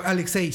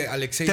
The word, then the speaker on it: Alexei: Ah, Alexei. Sí, Alexei.